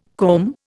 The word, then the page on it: comb: Indian